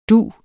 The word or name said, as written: dug